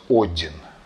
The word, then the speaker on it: Один: Ну, в общем, такая ситуация в некоторых источниках упоминается, и такие люди называются Один, что совпадает с именем древнескандинавского бога а может быть один. Но, ну, тем не менее, там, где я с этим делом сталкивался, там было ударение на первом «о».